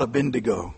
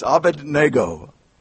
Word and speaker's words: Abednego: And you have three young men here, and let's try to correct our pronunciation. It's Shadrach, Meshach, and what? It's not Abednego. It's Abednego. Abednego.